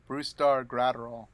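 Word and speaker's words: Brusdar Graterol: Brustar Graterol.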